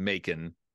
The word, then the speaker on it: Macon: With this team, it's pretty obvious that Bacon rhymes with Macon.